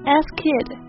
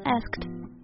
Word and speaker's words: asked: 对，很多同学会读成 ask kid，但其实只要在 ask 的基础上加一个清辅音 t 就好了，asked. – Right. The ed has a t sound because the k is unvoiced, so the d must also be unvoiced.